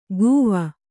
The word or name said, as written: ಕೂವ